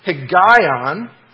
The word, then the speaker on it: Higgaion: This word meditation comes from a Hebrew word that you pronounce Higgaion, and you find that actually in English letters in Psalm 9, verse 16.